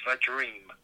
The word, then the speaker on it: Vadream: Moving down to the third leg, going to the 4 o'clock race, and the horse that we like here is Vadream, V-A-D-R-E-A-M, Vadream, not Vadream, Vadream, very weird pronunciation, or however words are.